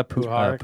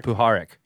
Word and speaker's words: Puharich: Uh, Puharich. Puharich. – Yeah. Yes. In UFOs Part 6, we touched upon Puharich with a primary focus on his communications with the Nine. However, it is ultimately Puharich the man who is vastly more historically significant.